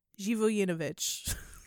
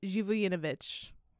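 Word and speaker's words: Živojinović: Because it's spelled Zivojinovic. Zhivoyinovich. There you go.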